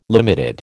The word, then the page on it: limited: American English